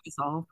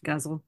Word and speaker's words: ghazal: The guzzle, it's spelled Ghazal. I thought it was Okay. – pronounced ghazal for a long time, but it's pronounced guzzle.